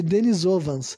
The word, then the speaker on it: Denisovans: Em inglês, na verdade, é Denisovans. Se escreve D-E-N-I-S-O V-A-N-S, sabe?